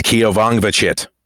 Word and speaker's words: Keovongvichit: It's Keovongvichit.